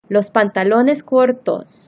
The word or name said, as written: los pantalones cortos